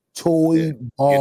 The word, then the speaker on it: Toybarr: Toy Bar.